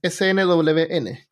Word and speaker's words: SNWN: Habían, a los médicos se les llamaban no tiene, no tiene sílaba esta palabra. Es SNWN, como Sun.